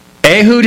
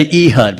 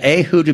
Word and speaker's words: Ehud: Ehud is correct. Very good. You even have the Hebrew pronunciation there. Some say Ehud, but yeah, Ehud would be more correct.